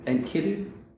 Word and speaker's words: Enkidu: How do you say Enkidu? Enkidu.